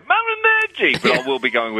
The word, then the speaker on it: Malignaggi: Malignaggi. That's how it's officially pronounced, I believe.